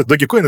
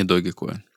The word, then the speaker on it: Dogecoin: И, кстати, Дим, а ты в курсе, что Dogecoin — это не Dogecoin? – В смысле? Не Dogecoin. – В том смысле, что мы его произносим неправильно. Он правильно произносится Dogecoin. Потому что изначально он основан на меме «Doge». Какой-то ребенок в школе неправильно написал слово «dog» и добавил «е» на конце. И получилось «Doge» в таком случае.